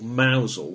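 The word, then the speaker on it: Mousehole: If you buy anything to eat at a place called Mousel, which for the Americans is spelled mouse hole, you really probably should know better.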